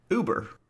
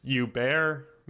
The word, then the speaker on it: Huber: it is spelled H-U-B-E-R. – Uber. – But this is Latin America. It's not Huber. It's Uber, maybe? – Really? – I think it's Uber, yeah.